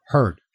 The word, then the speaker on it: Heard: Is it Heard or Head? – Heard. – heard. Oh, geez, I've been getting her name wrong.